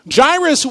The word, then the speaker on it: Jairus: So if you look at that name and say, I don't know how to pronounce that, I had to look it up too. Jairus, one of the synagogue leaders, came forward.